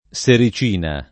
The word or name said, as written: sericina